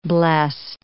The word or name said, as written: blest